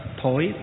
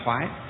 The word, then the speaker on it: thoái: Trong sách họ ghi là bất thối tâm, chứ thối ở đây là phiên âm theo tiếng miền Nam, miền Bắc chúng ta là thoái. Thoái, thối và thoái đều có nghĩa như nhau, nhưng cách phát âm thì miền Nam đọc là bất thối chuyển, miền Bắc chúng ta đọc là bất thoái chuyển.